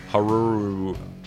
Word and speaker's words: Haruo: It was just a massive suit, heavy, and it was really difficult for the guy that performed in the suit, Haru Nakajima, I think is his name, is how you say it. H-A-R-U-O, and then Nakajima. I can say that one, I think. He had a real hard time moving around in this suit.